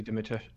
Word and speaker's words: Dimitrescu: Dimitrescu? – Yeah, Lady Dimitrescu.